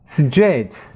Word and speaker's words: straight: Straight 直的直线的 Go straight on 直着走。 STR 在这里要发 str AIGH 就发 A，也就是 GH 不发音。